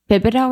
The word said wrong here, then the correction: Binibo: or something? I need to figure out how to say her last name. – Binibo.